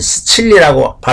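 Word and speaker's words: Sicilia: 이탈리인들은 시칠리라고 발음합니다.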